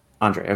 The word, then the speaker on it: Andrzej: Okay, and then how do you pronounce the first name? A-N-D-R-Z-E-J. – Andre, okay.